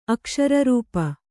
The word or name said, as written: ಅಕ್ಷರರೂಪ